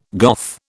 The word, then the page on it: Goth: British English